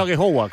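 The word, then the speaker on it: Aghehowa: Sí, yo sé que el apellido es un poco No sé si lo he pronunciado bien, pero he dicho Agejogwa, ¿cómo se pronuncia? – Sí, bien, la verdad que muy bien, así, muy bien.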